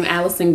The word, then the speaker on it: Allyson: You can reach out to me on LinkedIn under my name, Allison Graham, and it's spelled A-L-L-Y-S-O-N. Last name Graham, like Graham Cracker, G-R-A-H-A-M.